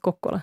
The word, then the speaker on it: Kokkola: orten som stavas Karleby men uttalas Kokkola.